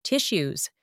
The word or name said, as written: tissues